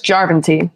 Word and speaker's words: Järventie: Järventie? No, it's Järventie. Sure. That's the first syllable in Finnish.